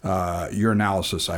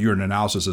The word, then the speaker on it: urinalysis: Urinalysis, I always mispronounce it. I call it urinalysis as if it's two words, but it's urinalysis, which is one word.